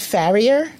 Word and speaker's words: Farrier: David Farrier. – Okay, that sounds like. – F-A-R-R-I-E-R. David Farrier. – That's definitely how he said it on the show. – Okay. – David Farrier, and he is from New Zealand, and he's a journalist, but I think he's also maybe a little bit of a comedian.